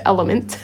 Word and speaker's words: LMNT: have informed me that it's pronounced element, which we didn't get.